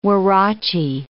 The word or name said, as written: huarache